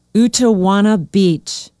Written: utowana beach

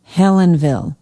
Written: helenville

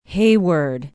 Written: hayward

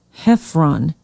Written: heffron

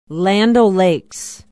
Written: land o lakes

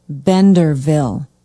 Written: benderville

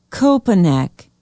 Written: koepenick

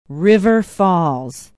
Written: river falls

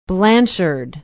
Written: blanchard

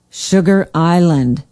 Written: sugar island

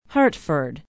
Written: hartford

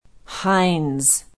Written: hines

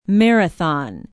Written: marathon

